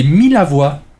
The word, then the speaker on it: Millavois: Non, les Millavois. Mi-à-voix, même peut-être. En tout cas, deux L, je ne sais pas si c'est mi-à-voix ou mi-la-voix.